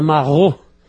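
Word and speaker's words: maraud: Amaral, existe uma palavra francesa, marrou, que se escreve M-A-R-A-U, porque A-U em francês se lê O.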